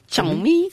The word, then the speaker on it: Xiaomi: Men hvis Apple ligesom kommer ind og bliver sådan et prestigeprodukt, hvad så med de kinesiske mærker som Xiaomi? Sådan tror jeg, det udtales. – Xiaomi.